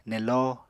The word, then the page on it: nello: Apatani